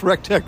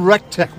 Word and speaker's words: Rec-Tec: The name Rec-Tec, where does that come from? – REC is Rachel, Evan, and Connor, the three kids of the founders. Tech rhymes with rec.